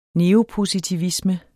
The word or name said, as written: neopositivisme